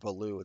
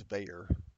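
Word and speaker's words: Veilleux: Not Valu, it's Veyer. Conveyor belt.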